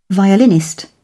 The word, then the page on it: violinist: British English